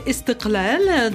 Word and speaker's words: Istiklal: où se joue la pièce Istiklal. Ça veut dire quoi, Istiklal? – Mais d'abord, c'est Istiklal, dans le bon accent arabe.